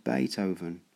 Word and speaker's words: Beethoven: He mentioned Beethoven. She had read in the library about the musician. His name was pronounced with an A and spelled with a double E.